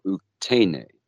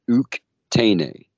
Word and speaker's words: Uktene: Here in Cherokee, we have what they call Uktene. And I'll say it again so people can understand the pronunciation. It's Uktene.